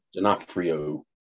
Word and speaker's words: D'Onofrio: D'Onofrio.